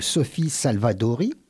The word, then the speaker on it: Sophie Salvadori: Donc, je reçois aujourd'hui à nouveau Laetitia Martigues, mais elle est, elle est accompagnée de Sophie Salvadori. C'est bien comme ça qu'on prononce votre nom. – Tout à fait, bonjour.